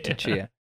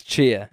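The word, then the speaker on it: Tchia: No, it's definitely not T'Chia. It's Chia. It's just Chia.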